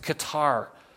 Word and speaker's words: Qatar: It's happening in a nation called Qatar. Some people pronounce it Qatar.